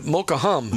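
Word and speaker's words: Mokahum: It may sound like you said Mocha Ministry Center, but Mocha-um. – Mocha-um, yeah. – Mocha-um. Oh, so the H is not pronounced.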